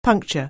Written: puncture

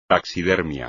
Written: taxidermia

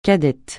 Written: cadette

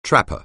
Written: trapper

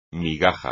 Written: migaja

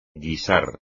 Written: guisar

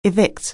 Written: evict

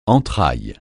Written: entrailles

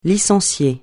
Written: licencier

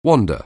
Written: wander

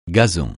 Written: gazon

